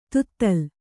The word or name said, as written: ದತ್ತೆಲೆ